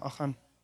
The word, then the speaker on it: Achan: But the thing is, Achan, I think that's his name, am I pronouncing? Yeah, Achan.